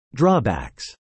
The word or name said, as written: drawbacks